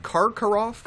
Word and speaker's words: Karkaroff: Karkov. – mm-hmm. particularly when I'm pronouncing right Karkaroff. – Karkaroff, yeah.